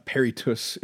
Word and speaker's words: peritus: or is that how you say it parody yeah that just means ex- experts. A peritus is an expert, and periti are experts.